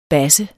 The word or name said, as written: basse